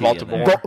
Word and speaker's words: Voldemort: How did you say his name? – Voldemort. – I don't think that's right, is it? – There's no T. – Voldemort.